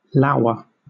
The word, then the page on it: lour: British English